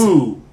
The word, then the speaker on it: Stu: Stu Grayson. – Stu Grayson. – Which rhymes with two.